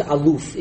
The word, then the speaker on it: aluf: And so here we look at this word aleph, and or almost aluf is the way it's pronounced.